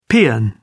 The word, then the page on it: peon: British English